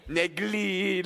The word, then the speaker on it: Negril: For a white boy, white boy like me, me have to say the L really hard, really pronounce the L. Negril.